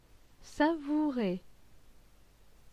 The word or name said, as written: savourer